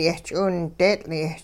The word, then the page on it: yehch'iwindeetL'-ee: hupa